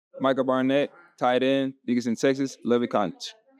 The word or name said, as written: Micah Barnett